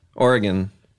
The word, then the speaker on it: Oregon: Oregon, where I live. That's how you're supposed to pronounce it.